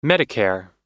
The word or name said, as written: medicare